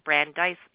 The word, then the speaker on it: Brandeis: And I also have to thank Agador because he helped me with the pronunciation of a Brandeis University. That's how you pronounce it, Brandeis University.